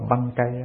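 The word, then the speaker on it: Bankei: người ta đọc là băng cây đó.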